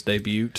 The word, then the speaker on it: debut: Debut. Silent T.